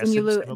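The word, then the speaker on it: Simu Liu: And then Simu, is that how you pronounce his name? – Yeah, Lu. Yeah, Simu Lu.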